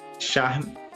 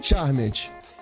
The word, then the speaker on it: Charmed: Apenas nessa quinta-feira foram anunciados cancelamentos de Legacy, Dinastia, é, Charme é, Charmed. Gente, desculpa se eu tô falando errado, tá? – Não. Charmed.